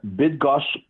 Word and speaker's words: Bydgoszcz: I am in Bydgoszcz, Poland, for the World Under 20 Championships in track and field.